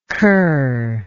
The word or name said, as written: cur